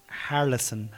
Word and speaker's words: Harrelson: No, Harrelson, Harrison.